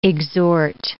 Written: exhort

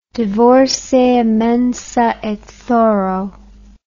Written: divorce a mensa et thoro

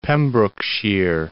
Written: Pembrokeshire

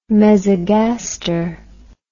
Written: mesogaster

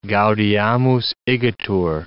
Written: gaudeamus igitur

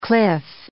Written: cliff

